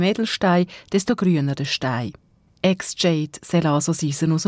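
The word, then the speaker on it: Exjade: Dann gibt es auch Wortspielerei wie zum Beispiel das Medikament X-Jade. Es schreibt sich X-Jade, wird aber eben X-Jade ausgesprochen. Das braucht man, um zu viel Eisen im Blut zu behandeln.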